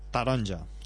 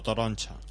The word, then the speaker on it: taronja: Taronja. Taronja. – Taronja. Ya tiene la tarjeta taronja. – O toroncha. – Toronja. – Puedes decir de varias maneras. Depende de la variante del catalán que hables.